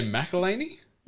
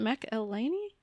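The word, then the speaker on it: McElhenney: The guy from Mac- I, I just say McElhaney. – Mac- is that how you say it? McElhaney? Oh, that actually makes a lot of sense.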